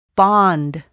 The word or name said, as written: bond